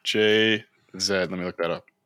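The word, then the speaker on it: Jay-Z: JZ.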